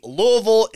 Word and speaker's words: Louisville: Generally speaking, generally speaking, Louisville is the accepted pronunciation.